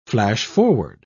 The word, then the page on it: flash-forward: British English